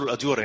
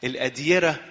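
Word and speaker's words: الأديرة: اشمعنى بتذكروا الاديوره يعني؟ بس هي مش اديوره، هي تنطق غلط. الاديره جمع ديار مش الاديوره.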